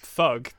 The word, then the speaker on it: THUG: Is that how you're supposed to pronounce that? – Thug. Yeah, Tony Hawk's Underground.